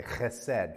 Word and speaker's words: chesed: In Hebrew, it's chesed. You have to say it like that.